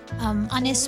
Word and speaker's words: Ynys: Anis Dos Pars Nos, we heard their song He Sin Cani, just meaning She Who Sings. It's a wonderfully melodic album, so do go check it out. Anis spelled Y-N-Y-S.